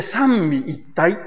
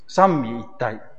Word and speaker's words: 三位一体: これで三味一体って読むんですよ。三味一体。